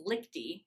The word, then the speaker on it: Lichty: how do I pronounce that? So it's Lichty, which is a very German last name, but he also is half Greek.